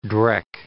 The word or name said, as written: drek